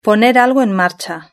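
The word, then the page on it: poner algo en marcha: Spain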